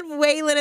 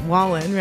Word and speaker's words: Wallen: When he said Wallen at the end, I'm like, no, he didn't. He didn't just say Wallen and not Wallen, right?